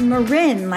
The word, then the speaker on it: Marin: It's Marin, like the county.